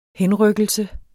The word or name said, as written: henrykkelse